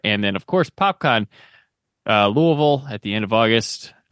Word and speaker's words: Louisville: You call Louisville. Oh man, you're gonna get punched in the face. You call it Louisville. – Oof. – They're gonna have a billboard out for you. But they pronounce it Louisville.